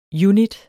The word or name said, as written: UNITTERNE